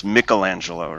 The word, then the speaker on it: Michelangelo: So, you like, the Renaissance artist is, it's pronounced Michelangelo, right?